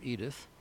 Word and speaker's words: Edythe: She spells her name E-D-Y-T-H-E, but I'm sure it's pronounced Edith.